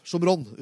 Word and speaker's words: Shomron: Vet du hva Samaria på hebraisk? Shomron uttales det.